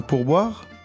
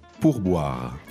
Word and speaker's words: pourboire: Un pourboire. – First, let's work on the pronunciation because it's a little difficult word. – Yeah. – We got some of those French R's in there.